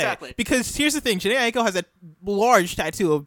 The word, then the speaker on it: Jhené Aiko: Jhené Aiko. – Jhené Aiko.